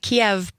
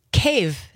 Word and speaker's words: Kyiv: And you are the founder and the CEO of Ukrainian Patriot, which is a nonprofit based in Kyiv, not Kyiv, not Kiev, but Kyiv. – There you go. – Is the appropriate way mm-hmm. to pronounce it. – Ukrainian transliteration. That's right.